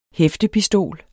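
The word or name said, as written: HÆFTEPISTOL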